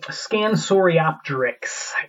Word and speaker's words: Scansoriopteryx: Uh, okay, so a question about the Scans Scansoriopteridis is how do you pronounce that damn species? – It's um, Scansoriopteryx.